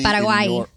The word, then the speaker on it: Paraguay: Paraguay.